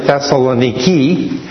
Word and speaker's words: Thessaloniki: Well, the old Roman city of Thessalonica lies underneath the modern city, which is the same name but kind of pronounced as Thessaloniki.